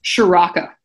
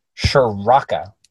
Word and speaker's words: Ciarrocca: Ciarrocca. Ciarrocca.